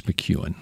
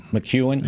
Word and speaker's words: McKeon: It, by the way, it's McEwen. – McEwen? Yeah. I know. Why, what did you call him? – It's spelled McKeon. – It's spelled McKeon. – Oh, right. – Here we go. It's M-C, capital K-E-O-N. How you get McEwen out of that, I don't know, but that's the way it is.